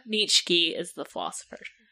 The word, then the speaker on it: Neitzke: N-E-I-T-Z-K-E. – I wasn't ready. – Nitsky. Nitsky? – N-E-I. – Nitsky. – Hang on, spell it again. – N. E-I-T-Z-K-E. – Nitsky. – Yep, she's right.